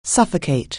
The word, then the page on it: suffocate: anglais